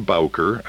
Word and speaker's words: Bowker: John Bowker, and I'm going to spell my last name, B-O-W-K-E-R.